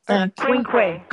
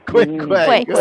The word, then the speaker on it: quinque: Cinque. – Quinque. – Quinque. Sorry. – That's all right. – How do you spell that? – Quinque. – Q-U-I-N-Q-U-E.